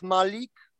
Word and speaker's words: Malik: My name is Przemysław Malik, but since my name is very hard to pronounce, I'm guessing you can just call me by my last name Malik.